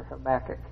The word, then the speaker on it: Habakkuk: Habakkuk 3, I'm going to begin with verse 1 in chapter 3 of, is it Habakkuk or Habakkuk? Habakkuk.